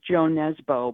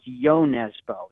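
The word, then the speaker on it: Jo Nesbø: It reads as Joe Nesbo, but apparently it's pronounced Yo Nesbo.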